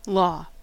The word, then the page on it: law: [ˈlɔː]; /ˈlɔ/; /lɑ/